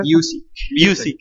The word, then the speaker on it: Busiek: A ver, enséñame a pronunciar a Kurt Busiek, a ver. – Busiek. Kurt Busiek.